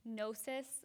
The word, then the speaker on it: gnosis: which would be gnosis. It's one of those tricky words with the silent G-N, like gnome or something like that.